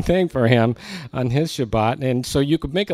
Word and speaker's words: Shabbat: And I was thinking, because the last Shabbat that I had, which is the way the Israelis pronounce it, the last one that I had, I went for a drive with my friends.